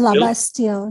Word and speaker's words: Labastille: And it was about a woman, and forgive me if I'm butchering the pronunciation, is it Anne Labastille? – Anne Labastille, yeah.